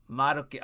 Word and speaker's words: Marroquín: But the point is, Matthew Marroquín, how are you? Am I pronouncing your last name correctly? – It's Marroquín for the last name. Matthew Marroquín. – Marroquín. Okay, got you.